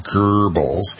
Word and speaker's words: Goebbels: Goebbels' name, an approximation of the German pronunciation, is Goebbels, has traditionally been synonymous and with propaganda.